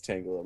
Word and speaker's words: Tangela: I've heard it pronounced Tangela, and it makes me cringe. Because it's like Angela with a T. – That's dumb. – Yeah, it's Tangela, my bad.